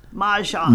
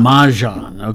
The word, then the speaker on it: Mahjong: Mahjong. – Mahjong.